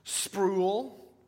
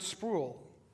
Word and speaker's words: Sproul: People would call him Sproul. And he'd say, "My name is not Sproul. It rhymes with soul. Sproul."